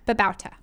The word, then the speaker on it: Babauta: Okay, so the joy is from the blog Zen Habits by Leo Babauta. I hope I said that right, Leo. – I think it's Babauta.